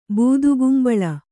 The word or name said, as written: ಬೂದಗುಂಬಳ